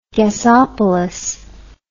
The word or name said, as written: gasopolis